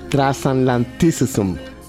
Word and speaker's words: Transatlanticism: El disco se pronuncia Transatlanticism.